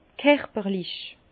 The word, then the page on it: kerperlich: Bas-Rhin; Herrlisheim